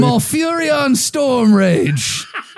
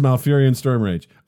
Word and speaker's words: Malfurion Stormrage: Malfurion Stormrage. did I do it right? – That was perfect. That's perfect. So Thrall versus Malfurion Stormrage. Okay, so Thrall is an orc shaman. – Yes. – Malfurion Stormrage.